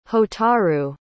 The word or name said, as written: hotaru